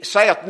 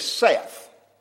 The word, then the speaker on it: saith: Now then, the very best scholars tell me that word S-A-I-T-H, proper pronunciation is Seth. You know, like the name Seth. But it looks like Saith to me. And I may call it Seth, and I may just call it Saith.